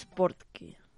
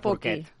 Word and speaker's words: Portkey: He pronunciat malament, és Portkey. – Portkey. – Sí. – Ah, no de no porquet.